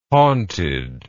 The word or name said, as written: haunted